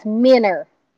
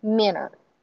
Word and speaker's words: minner: But it's minner, And the correct pronunciation of that would be minnow, but it is like all of the other words that end in an OW. It lines up with an ER, so it's minner.